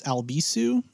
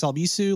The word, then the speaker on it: Albisu: You can follow me on social media by just searching for Alex Albisu, at Alex Albisu. Last name is spelled A-L-B as in boy, I-S as in Sam, U.